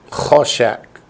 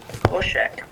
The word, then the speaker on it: choshek: The spelling is chol, C-H-O, shech, S-H-E-K, choshek. – Choshek.